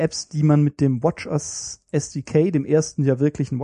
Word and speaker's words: watchOS: Und das Interessanteste eigentlich daran ist, es gibt jetzt auch das WatchOS für NativeOS, also natives SDK für das zweite WatchOS. Und da ist das Pflicht. Also bei allen anderen Korrektur, Apps. – Die richtige Aussprache ist WatchOS. – WatchOS.